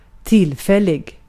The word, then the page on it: trial: [ˈtʃɹaɪ.əl]; /ˈtɹaɪ.əl/